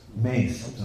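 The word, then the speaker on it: mace: So Ugrasen called the assembly and then it was discussed that let's destroy the mess. – What do you mean by mess? – It's a mess, gala, mushal. – Maize. – Maize. Okay, I'm sorry. Yeah, the pronunciation would actually be maize.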